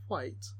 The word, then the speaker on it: white: But his hair turned white with fright. – White? – I always have to pronounce the H.